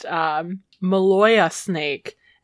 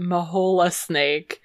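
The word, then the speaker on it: Maloja: I learned that it is pronounced Maloya Snake. And not Mahola Snake or Maloya Pass.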